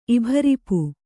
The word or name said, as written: ಇಭರಿಪು